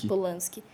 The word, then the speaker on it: Polanski: Polanski, não sei como pronunciar. – Polanski.